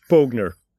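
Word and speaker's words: Boughner: Skandal här i uttalet, men jag säger Bob Bogner.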